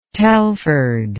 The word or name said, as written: Telford